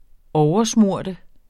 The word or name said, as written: oversmøre